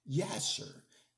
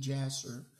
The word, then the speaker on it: Jasher: I mean, if you want to really get the detail of Genesis, then you got to read the book of Yasher in the Hebrew. Some people would pronounce it with a J, Jasher, and we need to study all of these great scriptures out there.